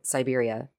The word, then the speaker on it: Siberia: Serbia. – Serbia? I don't think that's a different country. – It is. It is. Deep in the heart of Siberia sits this town, and it's the oldest city above the Arctic Circle.